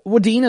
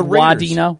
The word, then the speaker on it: Wadena: There is a lot of like W-A-H, Wadena, Wadena. – People really put an emphasis on the first part of the word.